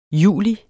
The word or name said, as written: juli